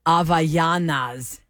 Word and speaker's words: Havaianas: No, this is how they're saying it should be pronounced. A-H-V-A-H. So Ava, Y-A-H, Ya, N-A-S, Nas. – Havanagila. – Havaianas. Havaianas is how we should say those flip-flops.